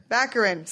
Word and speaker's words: Baccarin: Baccarin.